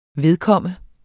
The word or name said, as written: vedkomme